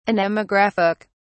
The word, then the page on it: anemographic: British English